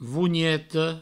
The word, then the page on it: vonheta: vougneto